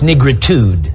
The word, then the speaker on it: nigritude: seal of the American Psychiatric Association declared that the color of blacks was caused by a rare congenital disease called nigritide. It would be funny if it wasn't so sick. It would be funny if it wasn't so sick. And I apologize, I mispronounced the word. It's nigritude. Congenital disease.